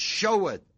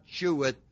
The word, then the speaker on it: sheweth: The word sheweth, or S-H-E-W, is not sheweth. It's like pronounced like S-H-O-W. Sheweth. The word sheweth is setting forth, setting forth.